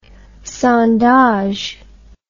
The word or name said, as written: sondage